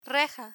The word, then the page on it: reja: Spanish